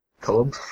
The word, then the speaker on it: Kolob: Kolob. – So why don't, now that you're on the air, why don't you take the opportunity to properly pronounce your name? – Okay, well, it's pronounced the Russian kolob.